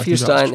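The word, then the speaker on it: Fierstein: Harvey ah, Vierstein, ja, Vierstein, genau, spricht man so aus.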